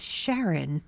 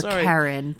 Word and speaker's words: Charon: Or Charon, depending on if you pronounce it with the hard C or the soft C.